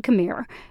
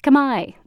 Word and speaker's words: Khmer: Most Americans are used to hearing it said Khmer because it's spelled with an R. It's actually pronounced Khmai, though, and I'll be saying it that way.